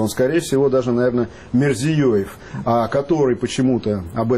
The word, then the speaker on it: Мирзиёев: У нас обычно его называют Мирзияев, что вызывает вопросы и даже напряжение в экспертном сообществе Центральной Азии. То есть ведущие эксперты говорят, почему путают фамилию? Он Мирзиёев.